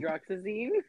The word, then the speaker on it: hydroxyzine: I had to pop my I think it's I always mispronounce it, like hydroxyquine or something. – Hydroxyzine? – Yes, that.